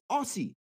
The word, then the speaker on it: Aussie: But you spell it A-U-S-S-I-E. It's Aussie. – Okay. – How you get me C? It. So two S's make it. A Z now.